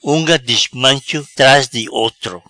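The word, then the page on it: Unga dismancho: Patua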